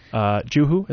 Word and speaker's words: Juhu: Juhu, is that pronounced correctly? – That's right.